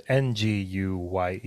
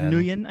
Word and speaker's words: Nguyen: You know, like Nguyen, and that's how I'm pronouncing his name. It's Nguyen. – It's Nguyen, I think.